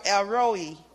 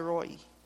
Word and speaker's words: El Roi: His eyes are on us, and that gives me comfort, because we serve Jehovah, which we serve El Roi, or El Roi. People pronounce it differently, but I say Roi.